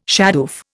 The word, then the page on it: shadoof: Indian